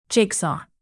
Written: jigsaw

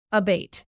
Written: abate

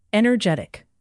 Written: energetic

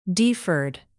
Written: deferred

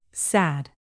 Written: sad

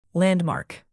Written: landmark

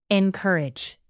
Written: encourage

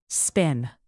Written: spin

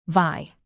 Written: vie

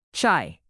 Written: chai

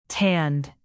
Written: tanned

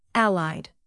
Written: allied

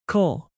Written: cole

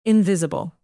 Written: invisible

